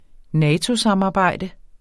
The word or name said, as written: NATO-samarbejde